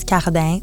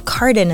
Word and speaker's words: Cardin: Yeah, I mean, well, the French-Canadian way to pronounce it is Cardin, but Cardin is perfectly fine.